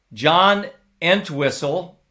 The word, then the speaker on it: Entwistle: Best bassist, John Entwistle.